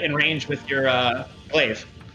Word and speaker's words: glaive: It's pronounced glaive.